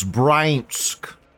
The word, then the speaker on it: Bryansk: anyways, fag flag first loli school shooter in history from Russia, an eighth grader from how do you say that word? Bezinsk Brzezinsk. Brzezinsk. You got to be the. Bransk. Bryansk. It's Bryansk. Eighth grader in Bryansk opened fire on boy classmates during a lesson.